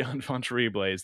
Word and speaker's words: enfant terribles: the enfant terribles. – The enfant terribles. They're you can probably get the French pronunciation correct on that. – No, that was perfect.